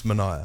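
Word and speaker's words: Manaia: Okay, my name's Manaya.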